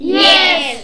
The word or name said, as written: yes